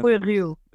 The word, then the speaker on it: Rui Rio: I'm going to mispronounce my Portuguese pronunciation is terrible, but is it Rui Rio? – Rui Rio.